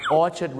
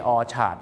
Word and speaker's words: Orchard: ะเราก็เป็นชาติเดียวที่ออกเสียงว่านาธานครับชาวโลกก็ออกเสียงว่า Nathan Road นั่นเองนะครับซึ่งชื่อฝรั่งนะครับเดี๋ยวถ้าผมมีโอกาสครั้งหน้าเราจะดูนะครับว่าชื่อฝรั่งนะครับหลายๆชื่อที่เราออกเสียงผิดมีอะไรกันบ้างแล้วฝากไว้หนึ่งชื่อครับ Nathan นะครับไม่ใช่นาธานครับแล้วก็อย่าลืมนะครับ Orchard Road ไม่ใช่ Orchard นะครับเพราะว่า ARD ต้องเป็นสระเออครับ